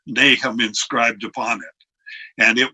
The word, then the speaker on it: Nahum: Now, Semitic languages don't use vowels. They only use consonants. And so NHM is actually pronounced Nahum.